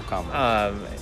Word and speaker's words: Kamala: It's Kamala, right? – I make sure Kamala, yeah. Kamala, yes. – Okay, make sure I'm pronouncing that right. – Yep, Kamala.